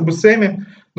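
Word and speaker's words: Buscemi: А за баром стоят два Пита, одного из которых играет Стив Бушеми, фамилия которого вроде как правильно произносится Бусеми, но я уже не могу перестроить мозг, поэтому буду называть его так, как называл последние лет 15.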